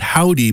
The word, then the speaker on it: Hovvdy: Not because they're not good, but because they call themselves Howdy, but they spell it H-O-V-V-D-Y. Two V's instead of a W.